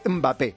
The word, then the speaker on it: Mbappé: A ver, puedes decir Mbappé, que empieza con M, no con E, o Mbappé, que te lo va a permitir cualquiera.